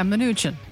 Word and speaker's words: Mnuchin: Make sure you go follow him as well over on Twitter, at Chadwick underscore Moore, M-O-O-R-E. And lots more coming next hour, including Sheriff Mark Lamb. And how do you pronounce Stephen Mnuchin? Stephen Mnuchin. – Yeah, Mnuchin.